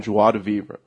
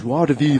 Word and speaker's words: joie de vivre: Joie de vivre. – How do you say that? What the? – Joie de vivre. – There we go.